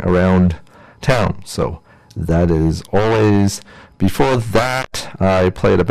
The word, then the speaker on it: Alvvays: They call Always. It's spelled A-L-V-V-A-Y-S.